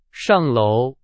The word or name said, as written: 上楼